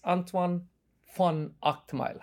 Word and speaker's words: Antoine van Agtmael: Those of you who are tuning into this episode of FO Podcasts should know that my guest is Antoine von Agtmael.